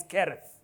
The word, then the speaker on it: Cherith: I know people pronounce it as Cherith, but it's Kereth. It's not Bahasa or English, it's Hebrew. Kereth.